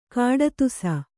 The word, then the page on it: ಕಡುದೇಸಿ: kaḍudēsi